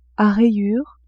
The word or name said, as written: a rayures